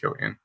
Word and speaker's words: Cillian: You say his name is, you pronounce it Cillian Murphy? – Pretty sure it's Cillian. Yeah, he's an Irish actor. I've seen him, not in person, but in interviews and stuff. And I'm 90% sure it's pronounced Cillian.